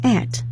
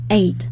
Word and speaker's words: ate: 英音呢读成 at 美音呢读成 ate 或者是 at 都可以。